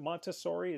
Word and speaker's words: Montessori: And then from there, it led me to, is it Montessori? Is that how you pronounce it correctly? – Yep, that's right.